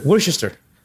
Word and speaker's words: Worcester: O la salsa inglesa, que es se escribe Worcester. – Worcester. – Pero es Worcester.